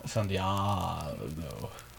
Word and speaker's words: San Diego: Comic-Con was this week in the San Diegers. – Yeah. Super cool. – It's pronounced San Diego. – San Diego.